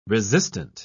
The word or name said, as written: resistant